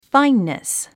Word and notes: fineness: fáinnis; ファインネス

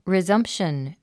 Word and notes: resumption: rizʌ’mpʃən; リザンプション

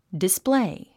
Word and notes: display: displéi; ディスプレェイ

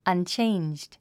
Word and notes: unchanged: ʌntʃéindʒd; アンチェンジド

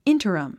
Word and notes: interim: íntərəm; インタラム

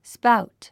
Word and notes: spout: spáut; スパァウト

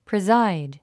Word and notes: preside: prizáid; プリザァイド

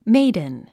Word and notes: maiden: méidn; メイドゥン

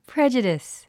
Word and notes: prejudice: prédʒudis; プレジュディス

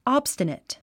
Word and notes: obstinate: άbstənət; アブスタネト

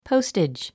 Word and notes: postage: póustidʒ; ポゥステッジ

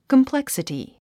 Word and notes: complexity: kəmpléksəti; コンプレクスィティ

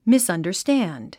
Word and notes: misunderstand: mìsʌ̀ndə r stǽnd; ミスアンダァスタァンド

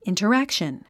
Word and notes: interaction: ìntərǽkʃən; インタラァクション

